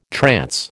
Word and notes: trance: American English

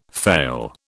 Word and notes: fail: British English

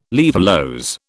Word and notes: laevulose: British English

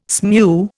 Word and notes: smew: Indian

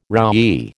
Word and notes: roughy: American English

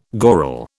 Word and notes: goral: British English

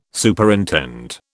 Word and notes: Superintend: British English

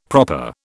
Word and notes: proper: British English

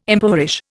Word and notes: impoverish: Indian

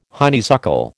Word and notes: honeysuckle: American English

